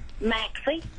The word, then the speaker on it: Maxie: Maxey. – Maxey. Spell that for me. – M-A-X-I-E.